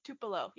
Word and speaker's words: Tupelo: and I can't even pronounce it. It's Tupelo? – Tupelo, yeah.